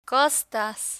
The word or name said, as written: costas